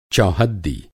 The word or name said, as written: चौहद्दी